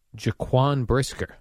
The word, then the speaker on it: Jaquan Brisker: I love Bear safety, Jaquan Brisker. – Nice job on the pronunciation. – Did I nail that? – Not Pau Gasol.